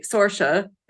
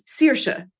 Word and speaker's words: Saoirse: Don't try to say, you know, if you say Saoirse instead of Saoirse, George Lucas, it's Saoirse, means freedom in Irish.